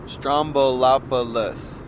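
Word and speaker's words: Stroumboulopoulos: Am I still saying it right? Stramanopoulos. – Strombolopoulos. – You know, I put an N in there. I went Stramanopoulos.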